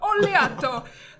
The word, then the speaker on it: Oleato: And you've got to say it like that as well. Oleato.